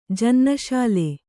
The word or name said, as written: ಜನ್ನಶಾಲೆ